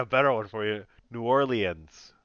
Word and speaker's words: New Orleans: The Ravens heading to New Orleans, however you want to say it. Ravens Saints. – Honey, you're a black man. Say it right. – I don't know. – New Orleans.